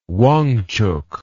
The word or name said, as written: Wangchuk